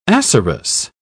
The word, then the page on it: acerous: British English